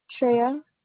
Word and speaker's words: Shraya: Shreya. – Spell it for me. – S-H-R-A-Y-A. – You said S-H-A? – S-H-R-A-Y-A. – A-Y-A.